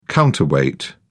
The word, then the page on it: counterweight: British English